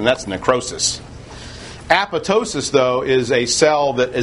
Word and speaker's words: apoptosis: This has to do with what is called apoptosis, an often mispronounced word. It looks like it's apoptosis, it's apoptosis. And apoptosis is programmed cell death.